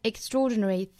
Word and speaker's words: extraordinary: About ordinary, 后面连读 about ordinary people 舌侧音 people 舌尖顶住上齿音哈 people doing 这个词大家要注意不要读成了 doing 而是有一个小小的窝在里面的这个 doing 后面 extraordinary 这个单词重读是在 draw 这个